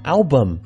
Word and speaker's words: album: 我们今天要聊到的是发行一个人的专辑，所以这个地方的专辑也可以来学习一下，专辑叫做 album， album， A-L-B-U-M， A-L-B-U-M， owl，这个地方的 L 发了一个翘舌音，把舌尖放在上排牙齿的后边， owl， owl，后面的 B-U-M，这里的 U 不会念啊，不念 bum 啊，念作轻的一个短的元音，叫做 a album， album Album 的最初的一个意思表示的是 a book in which you keep photographs, stamps, or stickers, etc.